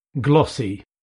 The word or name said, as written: glossy